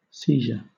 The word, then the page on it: seizure: British English